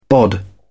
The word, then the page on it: bod: British English